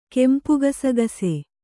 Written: ಕೆಂಪುಗಸಗಸೆ

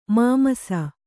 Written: ಮಾಮಸ

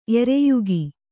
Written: ಎರೆಯಿಕ್ಕು

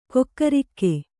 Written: ಕಕ್ಕರಿಕೆ

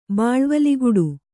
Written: ಬಾಳ್ವಲಿಗುಡು

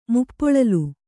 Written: ಮುಪ್ಪೊಳಲ್

